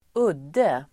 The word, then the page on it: udde: [²'ud:e]